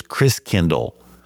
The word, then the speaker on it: Christkindl: And the German pronunciation of Christ child is Christkindl. Like kindergarten, kindercare, Christ means Christ, and Kindle means child, so Christ child.